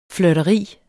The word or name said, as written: flirteri